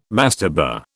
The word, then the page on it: mastaba: British English